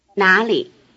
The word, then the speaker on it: 哪里: Notice that the tone pattern is rising, neutral. 哪里? This is because the syllable li is a basic low-tone syllable, and as you will remember, the first of two low-tone syllables changes to a rising tone.